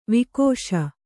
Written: ವಾಗೀಶ

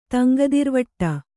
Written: ತಂಗದಿರ್ವಟ್ಟ

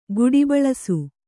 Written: ಕಾಡಬೆಳಸು

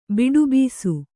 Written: ಬೆಟ್ಟುಬ್ಬಸ

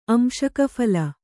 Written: ಅಂಶಕಫಲ

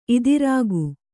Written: ಇದಿರಾಗು

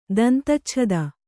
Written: ದಂತಚ್ಛದ

